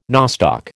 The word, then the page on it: nostoc: American English